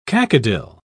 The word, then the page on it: cacodyl: British English